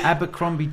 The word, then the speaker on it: Abercrombie: Abercrombie jeans on now.